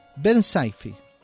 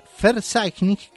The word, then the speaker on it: Verzeichnis: Es Bach, de la B de Bach, Werke, que es la W, y Verzeichnis, que es la B corta, que en alemán se pronuncia como F.